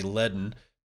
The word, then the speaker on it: Ledn: This morning, Toronto-based Bitcoin lending and savings company Ledin, that's spelled L-E-D-N, sort of like HODL,